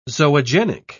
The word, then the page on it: zoogenic: British English